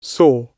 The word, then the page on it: saw: British English